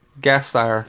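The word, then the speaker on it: Gasteyer: Anna Gastner? Is that how you say her name? – Gastner. – Yes, sir.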